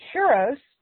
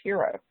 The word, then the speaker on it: gyros: If instead you're going to make gyros, which looks like gyro, folks, but it's pronounced hero, okay?